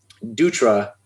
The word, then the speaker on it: Dutra: And I think it's because, for whatever reason, Dutra is hard for them to pronounce. So they spelled it as they would pronounce it.